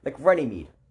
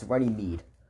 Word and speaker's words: Runnymede: like Runnymede. I called it Runnymed. Seems like it should be called Runnymed. It's Runnymede.